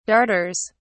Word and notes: darters: British English

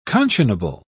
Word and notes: conscionable: British English

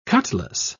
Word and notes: cutlas: British English

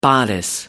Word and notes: bodice: American English

